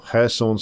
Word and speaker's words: Kherson: So if you look at the map, you can see Kherson City there, or Kherson City, if you prefer the Russian pronunciation.